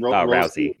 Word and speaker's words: Rousey: And that's probably a good thing, because I don't know, other than Rhonda, how do you pronounce her last name? – Rousey. Yeah.